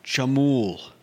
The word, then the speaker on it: chamoole: Today's word is chamul. – Is what? – Chamul. – Chamul? – Yeah. – How do you spell that? – C-H-A-M-O-O-L-E. – C-H-A-M-O-O-L-E.